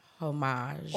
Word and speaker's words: homage: Today's Twitter Talk, well, first of all, Twitter Talk is the segment where we pay homage. – Homage. You don't pronounce the H in French.